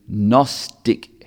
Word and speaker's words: Gnostic: That ticks the box against the Gnostic heresy. There's a silent G at the front of that word, Gnostic,